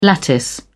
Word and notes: lattice: British English

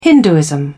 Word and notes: hinduism: British English

